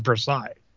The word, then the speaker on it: Versailles: And uh, if you remember, it was actually called, I mean, the, the French pronunciation is Versailles and I have something sticking to my lip that's driving me nuts.